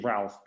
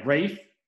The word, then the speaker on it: Ralph: I'm going to say Ralph because you haven't been, all right, but what's it called? Yeah, no, you're right. Yeah, Rafe. So it's spelled Ralph, but it's Rafe Nathaniel Twistleton Wickham.